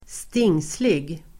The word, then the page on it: stingslig: [²st'ing:slig]